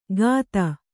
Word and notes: ಗಾತ: gāta

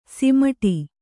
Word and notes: ಸಮ್ಮೂಢ: sammūḍha

